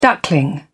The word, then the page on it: duckling: British English